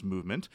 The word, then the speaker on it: MVMT: Movement Watches, spelled MVMT but pronounced Movement, was founded on the belief that style shouldn't break the bank.